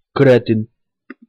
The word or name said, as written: kretyn